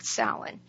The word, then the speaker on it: Samhain: which the Celts called Samhain. And it's spelled S-A-M-H-A-I-N, but pronounced like S-O-W-E-N.